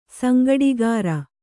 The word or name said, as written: ಸಂಗಡಿಗಾರ